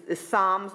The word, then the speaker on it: SOMS: So having said that, my two questions will really kind of focus on your, is SOMS the way you pronounce the acronym? – Yes.